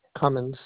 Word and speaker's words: Cummins: Cummins, C-U-M-M-I-N-S.